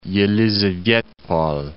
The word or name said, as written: Yelizavetpol